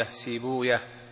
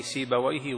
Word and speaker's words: سيبويه: وابن راهوية أهل الحديث ينطقونه بهذا النحو: راهوية، سيبوية، زنجوية. وأهل اللغة: ابن راهويه، زنجويه، سيبويه، وهكذا، لأنها من أسماء الأعاجم، أسماء الفرس.